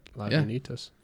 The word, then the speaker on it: Lagunitas: Lagunitas. – So I was right. Lagunitas.